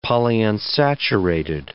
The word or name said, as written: polyunsaturated